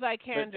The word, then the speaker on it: Vikander: Vikander.